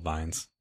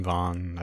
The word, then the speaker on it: vin: You've heard us say Vaughn a couple times, V-I-N, Vin, Vaughn. I've heard it pronounced both ways.